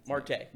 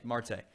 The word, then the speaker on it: Marte: How do you say his kid? – Marte. – Marte.